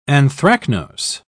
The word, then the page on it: anthracnose: British English